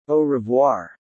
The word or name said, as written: Au Revoir